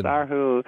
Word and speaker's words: Erhu: Yeah, is pronounced Erhu. I didn't know this at all.